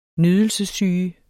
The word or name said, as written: nydelsessyge